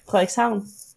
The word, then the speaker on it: Frederikshavn: I've been to Denmark actually once, but I only went to Frederikshavn. I don't know if I'm saying that right. – Frederikshavn, yeah.